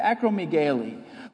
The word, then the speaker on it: acromegaly: That's how you say it, acromegaly, which is a cancer in the pituitary gland that causes you to either lose your vision or have double vision.